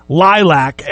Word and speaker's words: Lylvc: If you see this band printed, it's pronounced Lilac, L-Y-L-V-C, but it's Lilac.